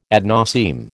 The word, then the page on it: ad nauseam: American English